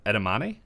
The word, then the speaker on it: edamame: Edamame? – Yeah, you got it. – Oh, sweet. Yeah, that was good, and I could see that being eaten as a snack. – I will eat edamame as a snack.